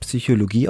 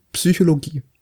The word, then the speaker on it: Psychologie: Es gibt ganz viele Leute, die sagen Psychologie. Die können kein Psi aussprechen. – Also ohne das P vorne.